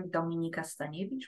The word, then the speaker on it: Dominika Staniewicz: And you can find me on LinkedIn under Dominika Staniewicz, which nobody can pronounce unless you're Polish.